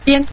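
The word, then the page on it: bian: tone 5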